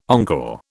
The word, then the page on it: encore: British English